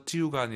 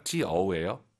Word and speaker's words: 就: 아이유 사이의 원어서 찌우가 아니라 찌어우예요. 찌어우 빠르게 발음해서 就.